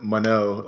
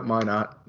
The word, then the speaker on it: Minot: or they always pronounce it Mano. Like, they like look at it like, oh, I gotta be, this place is fancy. Like, nope, just straight up Minot.